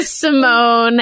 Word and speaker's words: Simone: I don't know why my parents put that accent there. But okay, the accent is silent. – Great. – Studio Simone and how that came to be and kind of where you're hoping to go with it.